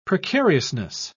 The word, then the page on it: precariousness: British English